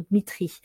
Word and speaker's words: Dmitri: chimiste russe Dimitri, alors ah, c'est plutôt Dmitri,